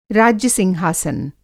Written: राज्य-सिंहासन

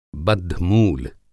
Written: बद्धमूल

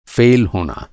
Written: फ़ेल

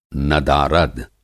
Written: नदारद

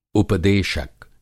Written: उपदेशक

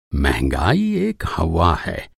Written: हौवा

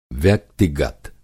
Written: व्यक्तिगत

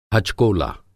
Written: हचकोला

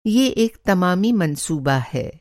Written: तमामी

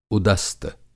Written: उदस्त

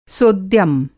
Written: सोद्यम

